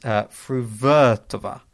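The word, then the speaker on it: Fruhvirtová: And he says Fruvertova, with the stress on the second syllable, the to being short like toggle, and the var being long like the var in Jamie Vardy, which I thought was a fun way of helping us there.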